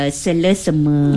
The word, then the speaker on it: selesema: Selesema. Selesema. Bukan selesema. – S-E-L-E. – Selesema. Itulah sebenarnya.